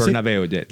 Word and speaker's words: Bernabéu: And this was at the Santiago Bernabéu, I'm not saying it right. – Bernabéu, that's right, Bernabéu.